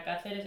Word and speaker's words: Cáceres: Tampoco pudieron pronunciar nunca mi apellido correctamente. Nunca era Cáceres, era Cacheres.